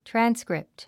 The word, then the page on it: transcript: trǽnskript; トラァンスクリプト